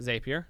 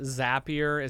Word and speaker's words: Zapier: If you're going to make it Zapier is happier, it's got to be Z-A-P-P.